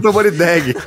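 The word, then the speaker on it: Tamborindeguy: Narcisa Tamborindeg. Tamborindeg. Ah, o, U é, é mudo. Entendi.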